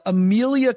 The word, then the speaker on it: Emilia: I saw that Emilia Clarke, I think that's how you pronounce her first name.